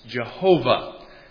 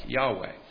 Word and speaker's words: Yahweh: It used to be pronounced Jehovah, but most scholars agree that probably the closest we can get to the original pronunciation would be something like Yahweh.